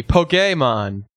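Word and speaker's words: Pokémon: Pokemon.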